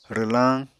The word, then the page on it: rwlañ: Apatani